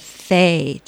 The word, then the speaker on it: fae: Can you'd mention there's a little bit of fae. Did I say that correctly? – Oh, yeah.